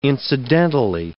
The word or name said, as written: incidentally